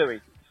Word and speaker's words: Louis: No, Louis. Silent S.